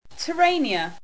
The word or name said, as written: tyrannia